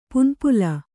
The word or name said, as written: ಪನಿಪುಲ್